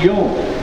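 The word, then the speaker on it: yom (יום): In Genesis chapter 1, the word for day is yom. Yom. And it's pronounced yom.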